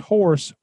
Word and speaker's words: Horst: They can't pronounce Horst. I tell them it's just Horst with a T on the end, but they still can't get it right.